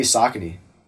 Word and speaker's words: Saucony: Definitely Saucony. And if I'm pronouncing that incorrectly, then... – No, that's right.